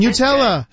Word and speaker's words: Nutella: Nutella, thank you very much. That's how you pronounce it.